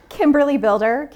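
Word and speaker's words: Kimberly Bilder: Kimberly Builder, KimberlyBilder.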